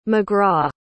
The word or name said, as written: mcgraw